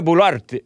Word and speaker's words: Boluarte: Dina, D-I-N-A. Boluarte. Dina Boluarte. Why am I saying it like it's Italian? Dina Boluarte. Boluarte. Boluarte. Sorry, guys. I get hung up on pronunciations. B-O-L-U-A-R-T-E.